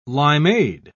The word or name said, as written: limeade